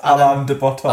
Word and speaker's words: Alain de Botton: So very Alander Button. – What's that? – I don't know how you pronounce his name. – Alain de Botton.